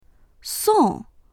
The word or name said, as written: song4